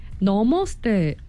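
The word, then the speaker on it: 너머스테 (नमस्ते): 근데 목사님 너머스테. – 예, 나마스테. – 네. – 너머스테가 너머스테. 네, 여러분 방송 들으시면서 새롭게 아실 것 같아요. 나마스테가 아니라 너머스테입니다.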